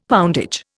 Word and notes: poundage: Indian

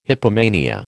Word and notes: hypomania: American English

